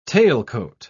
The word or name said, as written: tailcoat